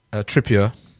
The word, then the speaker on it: Trippier: Trippier is the Motson pronunciation.